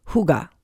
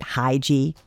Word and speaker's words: hygge: Like in Denmark, they have a word called hygge, H Y G G E, so actually it looks like you'd pronounce it hygie, either way.